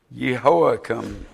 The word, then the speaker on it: Jehoiakim: but by the time of the evil king Jehoiakim is how it's pronounced,